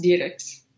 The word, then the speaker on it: Dierickx: And in Belgium, it's pronounced Dierickx. And in the United States, you can't get anyone to say that, nor do I attempt to do so. It's D-I-E-R-I-C-K-X. So we say Dierickx or Dierickx in the U.S.